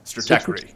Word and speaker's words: Stratechery: You know, and I think to the later article that you did send me from Stratechery, can I say that right? – Stratechery. – Stratechery, thank you.